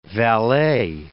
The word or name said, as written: Valais